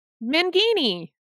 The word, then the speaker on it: Mangini: There's an opening for Dan McGinney. – Mangini. – I said it right. – No, you didn't. You forgot the N in there. Mangini.